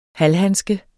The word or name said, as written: halvhandske